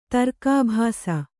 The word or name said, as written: ತರ್ಕಾಭಾಸ